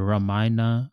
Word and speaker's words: Ramayana: Yeah, I remember you speaking about the Ramayana. Is that how you pronounce it? – Yes.